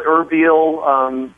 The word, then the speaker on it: Erbil: Is that how you pronounce it? – Yeah, they say it. It's said Erbil.